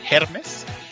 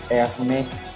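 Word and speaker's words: Hermès: Sí, o sea, en general, digamos, estas nuevas correas que está hablando Darryl van a funcionar con la empresa Hermes, que es la que se va a encargar. – Oye, te está saliendo del contexto, es Hermes.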